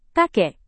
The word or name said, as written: kakek